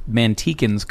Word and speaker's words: Manteca: I think you might be pronouncing it correctly, but Mantecans call it Manteca.